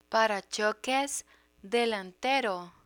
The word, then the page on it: parachoques delantero: Spanish